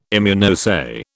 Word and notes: Immunoassay: British English

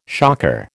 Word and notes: shocker: American English

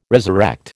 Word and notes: resurrect: American English

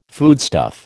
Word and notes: foodstuff: American English

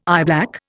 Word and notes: eyeblack: Indian

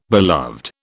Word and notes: beloved: British English

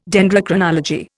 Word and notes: Dendrochronology: Indian

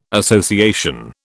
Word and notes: association: British English

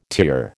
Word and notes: tier: American English

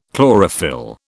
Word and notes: chlorophyll: British English

